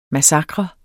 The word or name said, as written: MASSAKRERNES